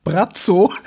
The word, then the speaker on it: Braco: Tatsächlich, Croatian Pronunciation Brazzo.